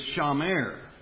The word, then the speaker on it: shamer: That word for cultivate in Hebrew is pronounced abad, and the word for keep or guard is shamer.